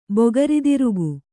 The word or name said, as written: ಬೊಗರಿದಿರುಗು